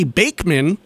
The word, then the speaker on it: Bachman: And we started the set off way back when with Randy Bachman.